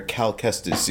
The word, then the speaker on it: Cal Kestis: Lucasfilm is actually planning on bringing, how do you say his name, Cal Kestis? – Jedi Fallen Order, Jedi Survivor, main character Cal Kestis, yes. – Cal Kestis to the live-action Star Wars universe, so let's see how that goes.